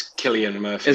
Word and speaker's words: Cillian: It was written by Alex Garland, and it stars, I don't know how to say his name, if it's Killian or Cillian Murphy. – It's Killian Murphy. – Is it Killian Murphy?